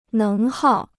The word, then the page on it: 能耗: néng hào